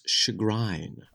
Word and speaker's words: chagrin: Oh, Nick, in England, it's chagrin. – Oh, is it? The E is pronounced there? – Yeah, the E is violent. – The E is, there's just an E there. It's one of those... We don't have the E, they have the E.